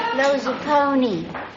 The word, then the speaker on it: Lozapone: How do you pronounce it? – Lozapone. – Gee, just like it sounds.